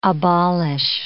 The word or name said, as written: abolish